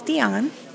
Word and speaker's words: Dionne: My first name is Dionne, D-I-O-N-N-E, and my last name is Puha, P-U-H-A.